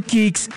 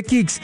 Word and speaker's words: Kixx: Protege tu moto con Kicks, aceites sintéticos y semisintéticos para un máximo rendimiento. Síguenos en Instagram como @aceite_kicks. Diego, ¿cómo se escribe Kicks? Ya le digo, se escribe K-I-X-X.